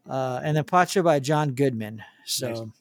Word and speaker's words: Pacha: Pacha? – Sounds good to me. – Pacha, yeah, I just watched it too.